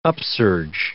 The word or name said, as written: upsurge